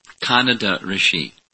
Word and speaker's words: Kanada: It's Kanada Rishi. I said it incorrectly this morning.